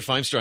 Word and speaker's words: Feimster: Is that how you pronounce her last name? Or Feimster? – Feimster. I know who you're talking about.